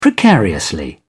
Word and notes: precariously: British English

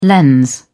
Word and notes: lens: British English

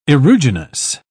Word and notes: aeruginous: British English

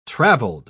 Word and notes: traveled: British English

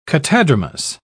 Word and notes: catadromous: British English

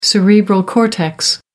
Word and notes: cerebral cortex: American English

